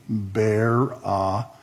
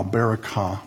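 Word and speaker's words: Berakah: There, Hebrew, from right to left, that's the B. That's the short E sound, vowel. That's the R. That's the short A. Barakah. That's the K or the hard C. That's short A. And that's the A. Barakah. Barakah. Valley of blessing.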